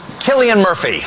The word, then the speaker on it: Cillian: Please welcome Cillian Murphy, the star of Oppenheimer. And yes, Stephen Colbert was correct.